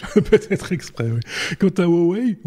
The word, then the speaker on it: Huawei: Donc c'est Huawei qu'il faut, qu'il, faut On n'a pas l'habitude nous hein, de dire ça. Oh, ah oui, ça c'est, la bonne, ce serait la bonne prononciation.